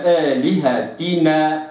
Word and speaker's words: آلهتنا: كذلك عدم إمالة الكسر في كلمات نحو آلهتنا، لا أقرأها آلهتنا، آلهتنا خطأ، وإنما آلهتنا هكذا.